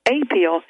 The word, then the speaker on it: Apeel: And I misspoke when I talked about appeal. It's appeal, capital A-P-E-E-L. The pronunciation is appeal,